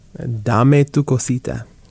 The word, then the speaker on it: Dame tu cosita: Dame tu cosita.